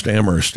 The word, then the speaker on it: Amherst: Amherst is Massachusetts, right? You pronounce the H here in New Hampshire.